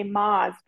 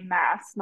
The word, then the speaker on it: Maas: Maas, which I have to say, I messed up on her name on our first podcast. I said Sarah J. Maas because I looked up a Google pronunciation, and I should have trusted the book talk girlies because they were right. Of course, Sarah J. Maas, not Maas.